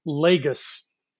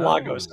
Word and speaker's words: Lagos: and it turns out the city's name is actually pronounced Lagos, not Lagos.